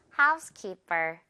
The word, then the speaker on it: housekeeper: 여기서도요, 뒷부분 per이지만 선생님 발음 어때요? per. housekeeper. housekeeper라고 이렇게 피읍 소리 억지로 내려다 보면 어색해지거든요. housekeeper. 자연스럽게 된 소리로 해주시면 돼요.